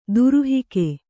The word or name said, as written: ದುರುಹಿಕೆ